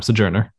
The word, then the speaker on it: Sojourner: You said it right the second time. Sojourner.